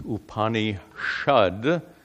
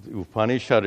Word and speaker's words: Upanishad: Now, it comes in Sanskrit, it comes as Upanishad. Well, there are grammar rules that force this sa to be pronounced as sha. That's due to grammar rules. So Upanishad is formed from two prefixes, upa and ni.